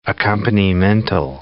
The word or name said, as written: accompanimental